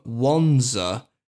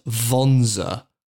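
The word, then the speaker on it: Wanzer: And it says, and I'm quoting the wiki here on the trivia section, the term Wonza should correctly be pronounced Vonza with a V.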